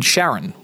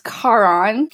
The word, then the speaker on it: Charon: Sharon with a C. What can you tell us about the growth out of the cloud technology and into some more custom development? – So it's actually pronounced Charon.